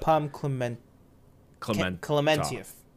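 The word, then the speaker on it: Pom Klementieff: Pom Clementief.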